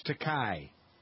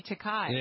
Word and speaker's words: Takei: It's really pronounced. – Is that how you pronounce it? – Yeah, but William Shatner ruined it for him because he always would say Takei. – Yeah, I always thought it was Takei.